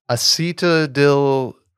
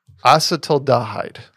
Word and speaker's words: acetaldehyde: Acetaldil acetaldehyde.